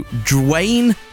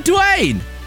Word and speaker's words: Dwayne: That's how you say his name. – You said Drain. – No, I didn't. I said Dwayne. Did I say Drain? – He started off Drew and then you went Wayne, like Dwayne.